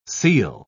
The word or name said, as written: seal